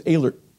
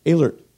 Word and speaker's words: Ehlert: Now, my last name is Ehlert. We had a thing, and now I know it's spelled with an O. Ignore that. E-H is A. You know the Canadian A? Okay, now you got it. Alert.